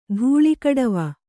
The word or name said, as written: ಧೂಳಿಗಡವ